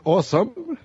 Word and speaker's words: awesome: Porque pues, es awesome, la pronunciación correcta, sí. con W, ¿no?